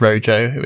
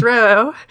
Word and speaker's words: Rojo: I mean, I'll be honest, I've been mispronouncing it all this time as Rojo, which I'm sure lots of people do. But some people get very competitive and argumentative about that, but it is supposed to be the Spanish color. So it's pronounced Roho, not Rojo.